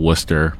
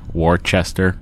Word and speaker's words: Worcester: And they call Worcester Worcester.